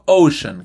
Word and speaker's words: ocean: Por ejemplo, ocean, que es océano. Ocean es con una c y se dice ocean, no ocean o ocean. Ocean.